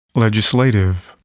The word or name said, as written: legislative